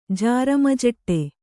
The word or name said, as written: ಜಾರಮಜಟ್ಟೆ